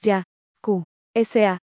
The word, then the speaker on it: Yakuza: Getty, la palabra Yakuza procede de los números 8, 9, 3. Pronunciados en japonés ya, ku, sa,